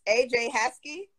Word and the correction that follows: Asia Sky: No, it's Asia Sky. – Asia Sky.